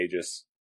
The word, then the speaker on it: Aegis: Before we even start, the secret society is called Aegis? – I'd say Aegis.